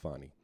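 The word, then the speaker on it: Fani: So yesterday is like, so the whole Fannie. How do you pronounce that shit? I think it's pronounced Fonny.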